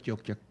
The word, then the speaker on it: Yogya: Gudeg Yoga? Yogya? – Gudeg Yogya.